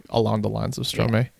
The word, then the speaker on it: Stromae: Stromae, yeah. – Yeah. – Is that how you say his name? – Stromae, yeah.